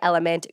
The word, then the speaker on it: LMNT: So if you guys want to try Element, go to drink. L M N T Yes, it's pronounced element,